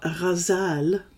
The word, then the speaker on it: Ghazal: This is Hip Hop Ghazal by Patricia Smith. I was about to call it the Hip Hop Ghazal, which is, you know, more Arabic pronunciation.